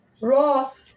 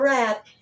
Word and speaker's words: wroth: Therefore the Lord heard this and was wroth. And it's spelled W-R-O-T-H. It's not wrath, it's wroth. Wroth.